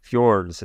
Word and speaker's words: fjords: Yeah, I've heard beautiful things about the fjords. Is that how they're pronounced? – Yeah, that's correct.